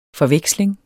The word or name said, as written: FORVEKSLINGERS